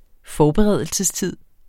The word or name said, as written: forberedelsestid